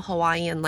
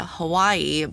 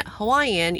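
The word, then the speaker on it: Hawaii (Hawaiʻi): So in traditional Hawaiian language, it's actually spelled H-A-W-A-I apostrophe I. So in English, you know, we pronounce it Hawaii, but in Hawaiian, you pronounce the W as a V, and the apostrophe signifies a pause. So you would pronounce it Hawaii, Hawaii.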